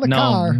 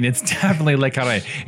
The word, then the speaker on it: Le Carré: Well, Le Car. – No, it's definitely Le Carré. It's not Le Car.